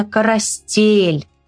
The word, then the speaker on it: коростель: В слове «коростель» ударение падает вот на звук «э», да, который обозначен буковкой «е». И ударение именно «коростель». Вот так вот правильно говорить «коростель».